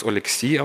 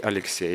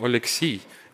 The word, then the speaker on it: Oleksii: ob das Oleksiy ausgesprochen wird, wie es auf Ukrainisch ist, oder gleich Alexei auf Russisch. Nein, ich bin nicht Alexei, ich bin Oleksiy.